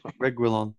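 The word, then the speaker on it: Reguilón: Reguilon. I can't say it. I don't know what's up with me. – Reguilon. – Reguilon. There you go.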